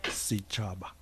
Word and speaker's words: Sechaba: Sechaba.